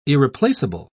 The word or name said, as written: irreplaceable